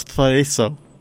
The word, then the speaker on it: chorizo: I believe it's Thoriso.